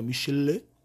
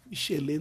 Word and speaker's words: Michelin: Eu não sei também se a pronúncia correta é Michelin, Michelin. Aí é francês, aí é mais complicado, é Michelin. Michelin não é nem fudendo.